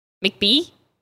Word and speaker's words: McBee: MCBEE. How do you say that? – McBee? – It's not how you say it. It's McBee. Damn, California. – It's McBee. – No, it's McBee.